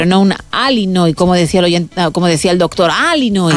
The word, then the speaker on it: Illinois: Pero es Illinois, o sea, si tú lo pronuncias bien y tienes buena dicción, es Illinois. – Illinois. – Luego, si lo pronuncias rápido, según el acento, como decía Beto también, pues te sale una medio E, pero nunca un Illinois, Illinois.